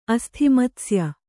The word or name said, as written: ಅಸ್ಥಿಮತ್ಸ್ಯ